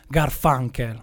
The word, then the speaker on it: Garfunkel: Io ovviamente ho fatto lo spelling, ma lo so benissimo che si pronuncia Garfunkel.